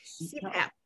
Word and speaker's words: CPAP: So I'm definitely going to look into a CPAP for myself, because how do I pronounce that? – CPAP. CPAP, continuous positive airway pressure.